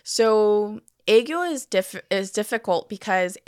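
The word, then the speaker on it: aegyo (애교): Okay, so sorry, I'm going to butcher the saying of this, but aegyo? – That was really good. – Oh, okay. – Wow. Yeah, aegyo. That's how you say it.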